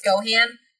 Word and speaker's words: Gohan: And I'm sorry, it's Gohan. You gotta say it right.